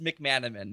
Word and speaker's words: McManimen: And am I saying your name right? I never said your last name out loud till today. – Yeah, it's McManimen.